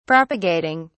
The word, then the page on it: propagating: British English